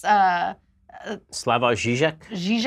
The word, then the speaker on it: Slavoj Žižek: Slavoj Žižek.